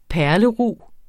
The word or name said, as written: perlerug